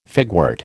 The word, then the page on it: figwort: American English